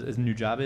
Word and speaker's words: Nujabes: It does remind me of Nujabes.